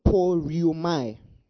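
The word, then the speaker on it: poreuomai: So the word I go here, I'll give you the word, is the word poriomai. Poriomai. Now I'll spell it for you. It's P-O-R-E-U-O-M-A-I. P-O-R-E-U-O-M-A-I.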